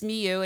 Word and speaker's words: Milhaud: He studied composition with Darius Milhaud, and it's spelled Milhaud for you, but it's pronounced Milhaud, and he is considered one of the top French composers of the 20th century, of the classic style.